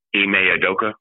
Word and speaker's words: Ime Udoka: Ime Udoka.